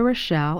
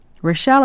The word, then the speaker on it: Rochelle: My name, Rita Rochelle, is spelled R-I-T-A. Rochelle is spelled R-O-C-H-E-L-L-E.